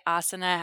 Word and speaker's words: asana: I will say asana has the emphasis on the first syllable.